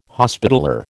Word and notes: hospitaller: American English

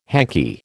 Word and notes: hanky: American English

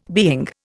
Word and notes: being: Indian

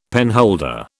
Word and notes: penholder: British English

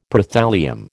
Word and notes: Prothallium: American English

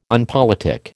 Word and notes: unpolitic: American English

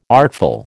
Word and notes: artful: American English

